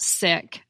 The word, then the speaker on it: sic: Next, let's talk about sick, which is spelled S-I-C.